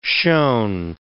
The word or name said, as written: shone